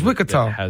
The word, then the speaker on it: Wichita: No, it's Wichita. Wichita, Kansas. – And that's how you pronounce it? – Yeah, it's Wichita. – Wakita? – Yep, Wichita.